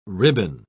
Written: ribbon

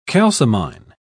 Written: calcimine

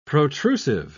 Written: protrusive

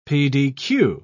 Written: pdq

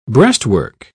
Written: breastwork

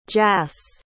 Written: jass

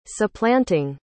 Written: supplanting

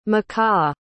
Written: mahkah

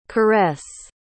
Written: caresse

